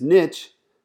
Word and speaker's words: niche: I like to say the word niche because niche rhymes with rich.